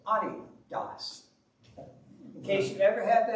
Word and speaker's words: Adidas: How many of you know how to pronounce the word Adidas? Adidas. Isn't that something? It's Adidas. Maybe you've heard that before. The name comes from the owner. He's from Germany.